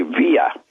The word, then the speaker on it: Villa: Villa is spelled V-I-L-L-A, and in most Spanish, that would be Villa. But to Argentine Castellano Spanish, it's two L's equals S. So it's Villa Langostura.